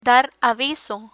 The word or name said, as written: dar aviso